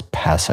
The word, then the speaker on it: Pesce: This is, it's Nicholas Pesce, I think is how you pronounce his last name. It's P-E-S-C-E.